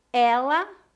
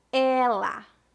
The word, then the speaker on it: ela: Ela com E aberto e ele com E fechado. Repita depois de mim. Ela, ela, ele, ele.